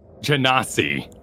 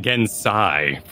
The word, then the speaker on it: Genasi: Yes, but I said it wrong for years in my head, so I need to look it up real fast. Hang on. No worries. Genasi. – Okay. – Because I called it Gensai for like my entire growing up childhood, so it's a little difficult for me.